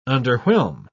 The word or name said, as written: underwhelm